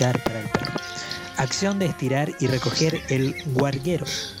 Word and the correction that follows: garguero: Gua no. Acción. corte, corte, corte. garguero. – Garguero.